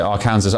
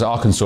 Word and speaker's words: Arkansas: Louisiana, Alabama, and not as I pronounced it, Arkansas, Arkansas, of course.